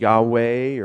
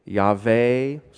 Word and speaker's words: Yahweh: That's the name of Yod-Heh-Vav-Heh, pronounced Yahweh, or maybe Yahweh. Some say Yahuwah.